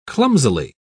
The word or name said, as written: clumsily